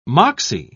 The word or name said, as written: moxie